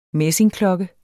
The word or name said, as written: messingklokke